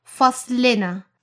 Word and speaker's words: فصلنا: A small tip to make your pronunciation easier. Add a small e sound before the ن. So it should be فصلنا.